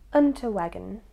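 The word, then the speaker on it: Unterweger: And also, interestingly, the hotel was a reported residence for the serial killers, Richard Ramirez, and Jack, and I absolutely cannot pronounce this, Unterwegeren? – Unterwegen. I feel like the accent has not helped that.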